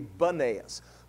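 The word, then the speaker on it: Banias: It was also called Peneus, or how you pronounce it sometimes over there would be Banaeus.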